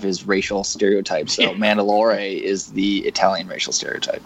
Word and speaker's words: Mandalore: Yes, that's how you pronounce it. So I'm glad you brought this up so you can just show how everyone is to pronounce it. – It's a planet. – Mandalore.